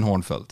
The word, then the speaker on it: Hörnfeldt: Just mitt efternamn brukar uttalas på alla olika vis som du kan komma på. – Men are you Captain Hörnfeldt? – Captain Hörnfeldt, ja.